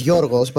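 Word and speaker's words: Yorgos (Γιώργος): Because George is not George, it's Yorgos, but we don't say Yorgos because you can't pronounce it.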